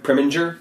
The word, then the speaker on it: Preminger: Pre- Prem- Preminger.